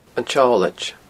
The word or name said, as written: An t-Seolaid